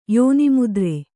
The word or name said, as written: ಯೋನಿಮುದ್ರೆ